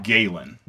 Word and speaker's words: Galen: I think I did meet him, but I pronounce it Galen. – Galen, yeah, Galen. – Like, yeah, yeah, okay. Yeah, I was just thrown off by your pronunciation. – Galen, so I started writing it down Gallon or Gallon, yeah. Like Talon.